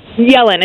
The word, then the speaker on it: Yellen: I mean, he's pointed to some comments by Janet Yellen basically saying that did I mispronounce her name? – Yellen. It's Janet Yellen.